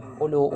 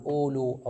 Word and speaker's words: أولو: طبعا هذه الواو بعد تعلمون لا تنطق، كما هي الحال في الواو الأولى في أولو، لا تنطق، فقط تكتب أولو أولات، لا نقول أولو أو أولات.